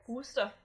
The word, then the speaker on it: Worcester: Worcester. – It's spelled Worcester, and that makes me frustrated.